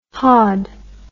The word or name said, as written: haud